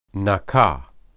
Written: nakah

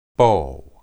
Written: bo

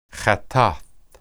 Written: hattath

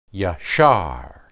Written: yashar

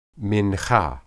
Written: minhah

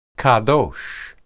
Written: qedosh

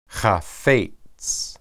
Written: haphets

